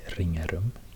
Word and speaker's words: Ringarum: And for example, in the place name, a small place, village called in, well, I would say Ringarum, because it's spelled with an R. Ringarum, two R's in this name. But then I've heard people saying something like Ringarum, Ringarum, Ringarum.